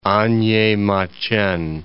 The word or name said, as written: A'nyemaqen